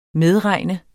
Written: medregne

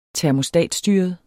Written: termostatstyret

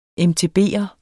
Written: mtb'er

